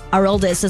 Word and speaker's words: Aroldis: Boston pitcher Aroldis, I know it's Aroldis is how they say it, I think Aroldis is how he would say it, Chapman, with his 400th career save in that one.